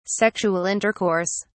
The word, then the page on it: sexual intercourse: British English